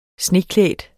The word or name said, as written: sneklædt